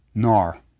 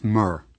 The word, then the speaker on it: Narmer: Now, the fish was pronounced Nar, and the chisel is pronounced Mer, Narmer.